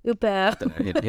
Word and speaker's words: Huppert: Huppert. You know, it sounds crazy. That sounded French, what I just did.